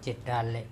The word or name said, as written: ceTale